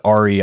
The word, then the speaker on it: REI: The other's wearing seven fleece, like a Patagonia over a REI? Raya? – Yeah. Yeah. Well, how do you say that? That clothing line. – The outside adventure brand, REI. Yeah. REI.